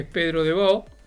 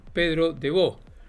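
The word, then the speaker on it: Pedro de Baud: que lo conoció debido a sus relaciones, sus viajes y demás, que es Pedro de Bo, y se escribe de Baud, Pedro de Bo.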